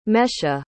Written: Mesha

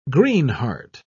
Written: greenheart

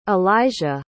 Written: alijiah

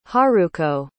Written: haruko

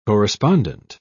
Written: corespondent

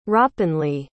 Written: hrapenly

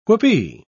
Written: whoopee

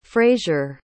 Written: frazier

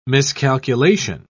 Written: miscalculation

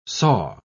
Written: saw

